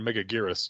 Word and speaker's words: Megaguirus: The Japanese, it always sounds more Megaguirus to me. That's the hard G instead of the soft G.